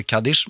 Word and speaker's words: Kaddish: Eh, och eh, om jag skulle uttala det, vilket jag kommer göra fel, skulle jag säga att den heter Kaddish, men du skulle säga att den heter? – Eh, helt rätt, oh, Kaddish.